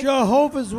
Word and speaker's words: Jehovah: 's a Javajo witness. – Is that true? You're a Javajo witness? – Jehovah's Witness, not That's Javajo. Right. Learn how to pronounce Jehovah God's name.